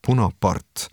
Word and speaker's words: punapart: punapart that is cited in the song. In fact, this is the Estonian way of pronouncing Bonaparte, and it means, directly translated, red duck in Estonian.